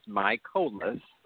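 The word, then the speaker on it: Mikolas: And I believe that the bringing in of Miles Michaelis, and by the way, that is how, you know, we were told to pronounce his name. It is Michaelis.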